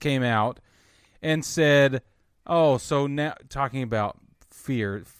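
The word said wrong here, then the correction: Fiers: How do you say his name? – Fires. – Yeah, leave me alone.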